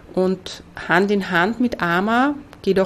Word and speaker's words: AMA: Ama. Oft wird das A am Ende gar nicht ausgesprochen hier, dass man nur Arm sagt. Ja, es kommt auch vor. Aber wir kennen es unter AMA, A-M-A.